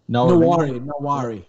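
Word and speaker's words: Nwaneri: Nawari, how do you say this kid's name? – Nawari.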